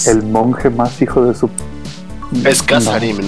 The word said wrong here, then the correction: Karasim: ¿no? A ver. – Es Karasim.